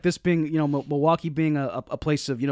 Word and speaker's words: Milwaukee: It's Algonquin for the good land, and it's pronounced Milwaukee.